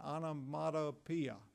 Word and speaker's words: onomatopoeia: How do you say that word again? Onomatopoeia. That's what it is.